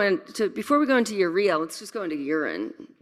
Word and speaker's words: urea: Is that how you say it, urea or urea? – Mm-hmm. Yeah, that's urea. Cool.